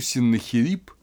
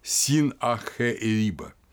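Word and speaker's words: Син-аххе-эриба: Синнахирип, Син-Ахериба, да, если правильно произносить его имя, разрушил Вавилон, стремясь уничтожить память о Мардуке.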